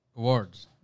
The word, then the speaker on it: Awards: Alors comment on prononce bien toi qui est anglophone parfait Awards Awards.